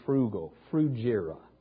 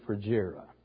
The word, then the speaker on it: Phrygia: And think of it as frugal, Phrygia. Phrygia is the pronunciation.